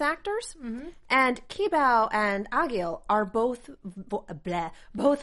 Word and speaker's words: Egil: Um, but we have a new another new character Egil or Agil? Agil. – Agil. Agil. Okay, yeah.